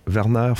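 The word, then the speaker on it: Wernher: Non, Werner, c'est un W. – Ah, oui, c'est vrai. – Je vais me reprendre, c'est Werner von Braun. – Oui, ouais, ton allemand n'était pas très bien prononcé.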